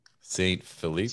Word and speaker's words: Saint Philippe: Dude, how would you pronounce S-A-I-N-T P-H-I-L-I-P-P-E? – Saint Philippe.